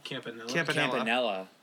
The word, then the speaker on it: Campanella: Is it Caminola? Roy Caminola? – Campanella.